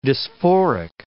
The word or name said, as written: dysphoric